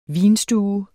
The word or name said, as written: VINSTUERNES